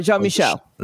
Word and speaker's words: Jean-Michel: Am I saying that right? – Jean-Michel. – Jean-Michel, there we go.